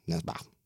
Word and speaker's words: Nesbø: It's like the O with the line through it, which I don't, how do you pronounce it? Nesbø. Nesbø. That's right. Nesbø.